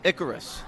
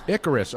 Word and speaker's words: Icarus: Icarus. – Icarus.